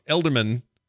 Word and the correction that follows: Edelman: who is that guy? – Ezra Edelman. Ezra Edelman.